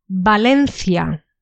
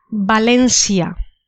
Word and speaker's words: Valencia: The next word has two different pronunciations. First one is the Castilian pronunciation, and the second one is a pronunciation for Latin America and some places in Spain. So the first pronunciation is Valencia.